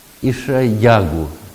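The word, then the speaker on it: Ишаягу (ישעיהו): Имя его по-еврейски Ишаягу. Ударение надо на «я» поставить.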